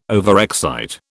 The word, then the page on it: overexcite: British English